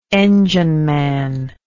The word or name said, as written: engineman